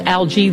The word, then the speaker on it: algae: The word algae can be pronounced two ways. Algae, the British English.